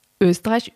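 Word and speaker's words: Oesterreich: Das Ehepaar Fred, Österreich übrigens ausgesprochen, Eckestrike. Ich weiß nicht, ob ich mir das merken kann, aber es ist eigentlich cool. Eckestrike. Wird aber geschrieben wie Österreich mit OE vorne.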